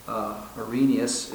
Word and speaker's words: Arrhenius: Arrhenius is the way I've heard that pronounced.